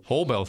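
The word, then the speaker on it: Haubeil: And the free gift we have to provide to you is a book by Helmut Hobel. Do I say it right? – Hobel. – Thank you.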